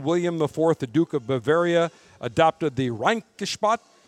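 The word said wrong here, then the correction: Reinheitsgebot: I believe I'm pronouncing that correctly. – Rheinhutzgebot. – Say that again.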